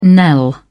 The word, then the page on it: knell: British English